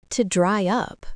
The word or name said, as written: to dry up